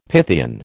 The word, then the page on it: Pythian: American English